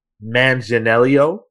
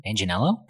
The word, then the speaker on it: Manganiello: So if you want to up the ante, Joel Manganiello, I think that's how you say his name. – Manganiello, probably Manganiello.